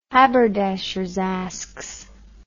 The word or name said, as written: Haberdashers' Askes